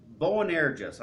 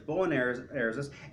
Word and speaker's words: Boanerges: And the name he gave them, if I can pronounce it correctly, is Boanerges. I always pronounced it differently, but it's Boanerges.